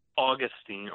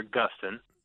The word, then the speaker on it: Augustine: So there's a great church father named Augustine or Augustine. You can pronounce it either way.